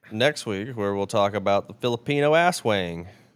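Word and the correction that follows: aswang: I think it's pronounced Oswang.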